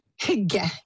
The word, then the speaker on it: hygge: It's pronounced hygge, yes.